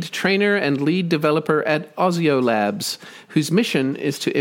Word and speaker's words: Osio: And then Ozio, we say Ozio Labs, O-S-I-O.